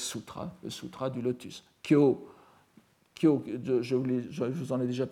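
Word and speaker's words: kyo (今日): Bien qu'il s'écrive avec deux orthographes différentes en japonais classique, kyo au sens de aujourd'hui, ça s'écrit kefu et ça se prononce kyo, et au sens de sutra, ça s'écrit kyau, et ça se prononce aussi kyo.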